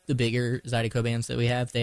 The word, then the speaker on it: Zydeco: Is it pronounced Zydeco dance? Mm-hmm. – Yes.